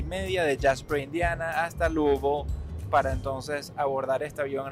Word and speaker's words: Louisville: Se en inglés cualquier persona lo pronunciaría Louisville ¿ok? Pero así lo pronuncian la gente que no es de Louisville. La gente que es de Louisville lo pronuncian Lubo.